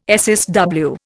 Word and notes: SSW: Indian

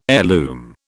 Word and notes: heirloom: British English

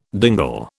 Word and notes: dingle: British English

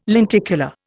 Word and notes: lenticular: Indian

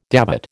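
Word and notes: dévot: American English

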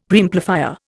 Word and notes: Preamplifier: Indian

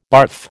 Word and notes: barf: American English

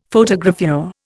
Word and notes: Photogravure: Indian

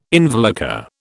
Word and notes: involucre: British English

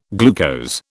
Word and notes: glucose: British English

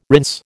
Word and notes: rinse: American English

